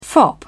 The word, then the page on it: fop: British English